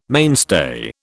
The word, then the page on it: mainstay: British English